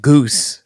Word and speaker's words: Gose: I'm extra good because I'm drinking a very pretentious sounding beer, a Goza. – Yeah. – It's spelled G-O-S-E. – Yep. – It's closer to like goose than Goza.